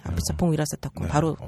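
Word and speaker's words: 아피차퐁 위라사타쿤 바로 (อภิชาติพงศ์ วีระเศรษฐกุล): 그 우리 풍 형님을 플레임으로 제대로 발음하시는 분을 이제 두 번째 보네요. 저희가. – 예, 네, 저도 처음에 이 감독님 성함을 접했을 때 그냥 아피차퐁 했었는데 계속 부르다 보니 아피차퐁 위라사타쿤 바로 나오게 되네요.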